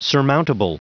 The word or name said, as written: surmountable